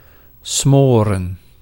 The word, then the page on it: smoren: /ˈsmorə(n)/